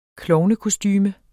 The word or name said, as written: klovnekostume